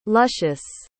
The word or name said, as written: lucious